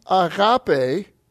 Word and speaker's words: agape: Now, the noun form, agape, and again, there's your pronunciation.